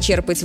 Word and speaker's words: черпать: Можно черпать воду из ведра, а можно черпать вдохновение или знание. Чтобы запомнить, помогает простая мысль. Если вы что-то берете из глубины, ударение тоже остается в основе.